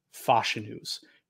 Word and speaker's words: Fashanu: Saying that, I think that his ceiling is much lower than Fashanu's. Right. Or I keep saying his name wrong. I apologize. – Yeah, that's it. That's it. You got it.